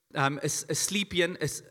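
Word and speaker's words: Asclepion: They had the Asclepian, is how you pronounce it.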